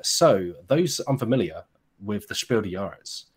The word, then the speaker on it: Spiel des Jahres: Spiel des Jahres. – Des Spiel des Jahres. – You say the S. Des. – Des. Spiel des Jahres.